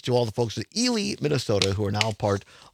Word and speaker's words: Ely: I will say, though, Phil in Minneapolis just called in and says it is pronounced Ely. – Oh, really? Eli. Well, see, immediately, that's another reason the people in Ely don't want me there, is because I can't pronounce their town name.